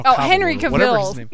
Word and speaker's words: Henry Cavill: Henry Cavill.